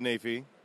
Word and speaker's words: Naifeh: Like, I've always been calling you Ted Neff, but it's Naifeh. So I'm glad that I know how to say your name, like, correctly now. Thank you. – Nobody gets it right. It's one of those names that does not sound like it's spelled.